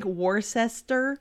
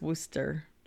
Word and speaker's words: Worcester: It looks like Worcester, but I know it's Worcester. Like, right? Isn't that how they say it? Worcester? Worcester.